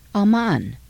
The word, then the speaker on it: Amman: The capital of Jordan today is pronounced Amman.